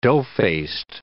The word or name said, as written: dough-faced